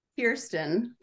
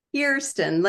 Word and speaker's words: Kirsten: Kirsten Tulsian. – Wait, how do you say it? – Kirsten, like ear.